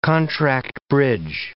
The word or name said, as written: contract bridge